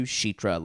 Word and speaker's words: Chitra: Chitra's name pronunciation is closer to Chitra, like the CH sound for Chicago.